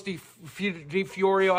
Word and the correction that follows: DiOrio: I don't know how to pronounce him. – He doesn't even know Nicholas DiOrio's name.